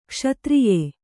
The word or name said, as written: ಕ್ಷತ್ರಿಯಿ